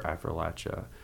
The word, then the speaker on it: Appalachia: Love his children's book, A is for Appalachia.